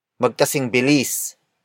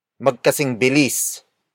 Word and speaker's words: magkasing bilis: Magkasing bilis ang tigre at ang zebra. Magkasing bilis ang tigre at ang zebra. The first word is magkasing bilis. Magkasing bilis. Magkasing bilis.